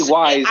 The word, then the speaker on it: Wise: Is it Jake Wise or Jake Weiss? – It's spelled Wise. It should be Wise.